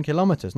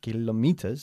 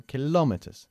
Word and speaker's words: kilometers: I can run 10 kilometers. No kilometers, sino kilometers. – Kilometers. – Enfatizamos la segunda sílaba nosotros, ¿eh?